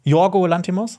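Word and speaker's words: Yorgos Lanthimos: Jorgo Lantimos? – Ja. Nicht Jorgos Lantimos? Ja, das hat Emma Stone nämlich in ihrer Rede gesagt, dass der Jorgo ausgesprochen ja. wird.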